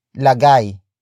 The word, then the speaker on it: lagay: Lagyan comes from the root word lagay.